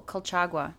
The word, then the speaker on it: Colchagua: How do you say where the valley? – Oh, Colchagua.